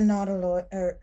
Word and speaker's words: nautiloid: It's a nautiloid. Sorry, how do you say it? – Nautiloid. Like nautilus. Yeah. Like a nautilus shell.